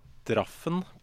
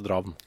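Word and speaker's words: Drafn: Altså, det skrives... Draffen på en måte, men uttales Dravn.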